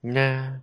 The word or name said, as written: nya